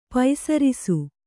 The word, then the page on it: ಪೆಸರಿಸು: Kannada